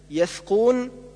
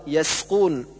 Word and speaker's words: يسقون: والعكس كالنطق بالسين ثاء، مثل يثقون، والصواب يسقون.